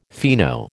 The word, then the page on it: fino: American English